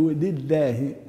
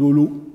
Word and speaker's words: لله: لابد من كسر اللام الأولى. الحمد لله، الحمد لي وليس الحمد له، الحمد لله. طبعا هي الضمة تسحب، ضمة الدال تسحبها على اللام، وهذا يسهل في النطق، ولكن لابد من ملاحظة أن اللام الأولى هي لام مكسورة، الحمد لله.